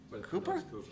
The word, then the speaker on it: Cowper: C-O-W-P. Cooper? – He pronounced E-P-R.